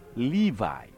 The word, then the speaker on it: Levi: Sons of Levi、日本語ではレビと書かれていますが、これはレビの輩から英語読みですから、 Sons of Levi と発音します。